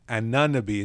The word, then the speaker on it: Anunoby: I think OG Anunoby is how it's pronounced there. But I think I'm pretty sticking to that pretty closely. Either way, Anunoby, his size difference, his strength, his quickness was really hindering Bam early on, and I think Bam was eventually able to compensate.